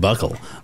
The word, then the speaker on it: buccal: Apparently it's pronounced buckle. Of his buccal fat. – Oh, buckle.